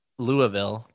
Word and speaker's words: Louisville: Which, Louisville. Did I say it right? Did I get it that time? – You did. You did. You got it.